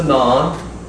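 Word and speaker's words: nun: This one is, this is the non. The non has a value of 50. Non or noun, it can be pronounced either way, non or noun.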